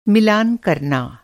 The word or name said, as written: मिलान